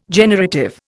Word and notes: generative: Indian

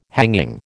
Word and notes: hanging: American English

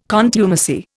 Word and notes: contumacy: Indian

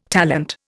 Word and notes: talent: Indian

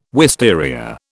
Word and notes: wisteria: British English

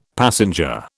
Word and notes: passenger: British English

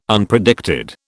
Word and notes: Unpredicted: British English

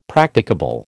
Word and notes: Practicable: American English

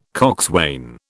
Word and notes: coxswain: British English

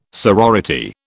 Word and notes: sorority: British English

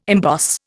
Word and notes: emboss: Indian

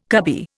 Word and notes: cubby: Indian

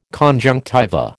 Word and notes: conjunctiva: American English

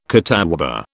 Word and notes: catawba: British English

